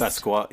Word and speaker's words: Basquiat: I mean, I had something similar like 2 weeks ago at this how do you say it? Basquiat? – Basquiat, the artist? – Basquiat. Yeah, yeah, the artist. – I think it's Basquiat.